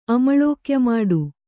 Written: ಅಮಳೋಕ್ಯಮಾಡು

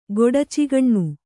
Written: ಗೊಡಚಿಗಣ್ಣು

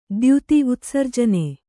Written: ದ್ಯುತಿ

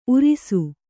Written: ಊರಾಸೆ

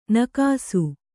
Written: ನಾಗೇಸಿ

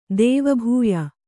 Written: ದೇವಭೂಯ

